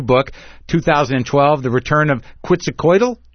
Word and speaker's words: Quetzalcoatl: I think it's Quetzalcoatl.